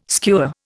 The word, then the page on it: skua: Indian